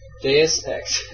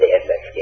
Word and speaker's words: Deus Ex: Deus Ex.